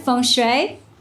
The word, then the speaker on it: feng shui: You know the word feng shui? Yeah, it's Chinese. Feng shui. But if I say it like that, Americans go, no, no. It's pronounced as feng shui.